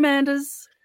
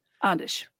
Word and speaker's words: Anders: I know they call him Anders, but it's actually pronounced Andish.